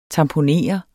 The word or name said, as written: tamponer